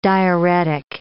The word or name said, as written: diaeretic